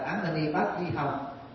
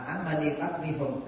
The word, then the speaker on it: Ám A Di Pháp Ni Hùng: thì chúng ta thấy ngài không đọc là Ám A Di Pháp Ni Hồng mà Ám A Di Pháp Ni Hùng, có phải không?